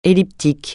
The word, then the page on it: elliptique: francais